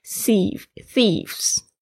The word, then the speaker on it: thieves: Are thieves，这边要注意一下 thieves 也是比较难念的， T-H-I-E-C 这个 TH 的声音是 th, 稍微咬轻轻的咬住舌尖然后突出的气音 th, th, Eves，然后 V 也比较难发，它是要轻轻的咬上嘴唇，哦，不要念成我的声音 Thieves OK。